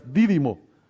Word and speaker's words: Dídimo: ¿No tiene acento? ¿Tú cómo se pronuncia? ¿Didimo? Oh, sí tiene acento en la I, pero gracias. Didimo.